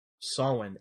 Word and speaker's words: Samhain: I think some people can pronounce it Samhain. I'm not sure, but I think the proper Gaelic pronunciation is Samhain.